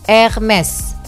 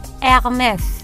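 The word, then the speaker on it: Hermès: So, it's a silent H sama S-nya kesebut. Hermes. – Hermes.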